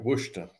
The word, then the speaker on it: Worcester: This song written by a guitarist from Worcester. That's right, Worcester. That's how they pronounce it.